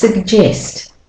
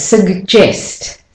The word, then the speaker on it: suggest: So the sug, the a is not saying a, I don't say sag-gest. It's sug. So that's the de-stressed syllable, and the e is saying e in jest. You can hear the e. So that the so it's suggest, suggest, not suggest, yeah, suggest.